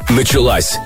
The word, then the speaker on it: началась: Правильно говорить «началась». В женском роде у таких глаголов ударение убегает в самый конец.